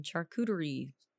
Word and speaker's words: charcuterie: and they have like these really random like events of like hey come get free wine and charcuterie, charcuterie. – items whatever yeah. however you pronounce charcuterie char charcuterie. you said it right the first time i think